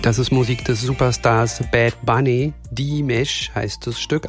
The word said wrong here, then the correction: Diles: Anna, habe ich es richtig ausgesprochen? – Nee, hast du falsch. Nein, falsch. Das heißt Diles.